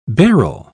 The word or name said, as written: Beryl